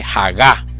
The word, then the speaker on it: hagá: como dije, hagá, con tilde en la A.